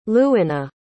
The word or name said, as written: lewanna